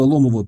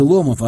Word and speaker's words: Ломов: На автобусе от Москвы до Нижнего Ломова пилить часов 5-6. Мы доехали меньше, чем за 4. – «Они по-другому город называют. Ударение на последний слог ставят», — говорил Газильс. «Не Ломов, а Ломов».